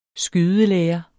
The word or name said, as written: skydelære